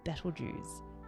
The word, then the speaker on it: Betelgeuse: This is Betelgeuse.